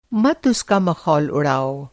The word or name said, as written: मख़ौल